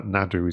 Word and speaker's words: Naru: And going back to the very first one with the character of Naru, I'm told that Nadu is the way you pronounce it in Comanche.